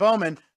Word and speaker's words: Alex Bowman: The one we pointed out yesterday, you got Ty Gibbs, Ken Harvick, Joey Logano, Alex Bowman, not sure how you pronounce it. – Bowman. – There you go.